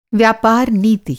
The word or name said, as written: नीति